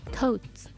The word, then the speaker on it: totes: ヤング英語は totes totes これは TOTES でトートバッグとかのトートに S をつけた感じの発音で totes